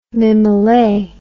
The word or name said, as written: mimollet